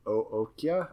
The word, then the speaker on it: Okja: Also, he collaborated with the director of Snowpiercer on a show for Netflix called OKJA, which I think is pronounced Ookja.